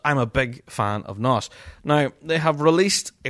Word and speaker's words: Gnoss: First of all, new music will be released very soon, actually, by the band Nos. That's spelled G-N-O-S-S.